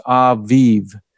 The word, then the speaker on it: Abib: And as you look at that word, Aviv, A-B-I-B, it is a Hebrew word, and for the month, the first month. And I was looking up today how it's pronounced. It's Aviv, and that's a more accurate pronunciation.